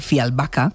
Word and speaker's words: Fjällbacka: Pues resulta que los crímenes de Fjällbacka, que es como se pronuncia, ella nos lo dijo así la última vez, están y vuelven esos protagonistas de todo esto y también, vamos a añadir, de misterio, de una cuenta pendiente del pasado.